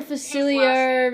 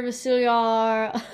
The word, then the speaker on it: Facilier: Dr. Facilier. Facilier.